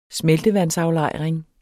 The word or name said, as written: SMELTEVANDSAFLEJRING